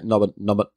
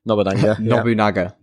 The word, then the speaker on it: Nobunaga: I probably butchered his name there. – Yeah, Nobunaga. – Nobunaga. Nobunaga.